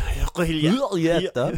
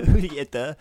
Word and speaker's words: Julieta: Julieta is the latest film from, as you pronounce it, authentic Spanish pronunciation. Yeah, with a little gulp. Julieta. – Julieta.